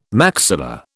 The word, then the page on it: maxilla: British English